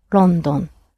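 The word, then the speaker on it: London (ロンドン): And the capital of England is London, which in Japanese is pronounced London.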